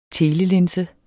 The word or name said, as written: TELELINSE